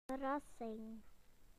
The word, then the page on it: surprise: British English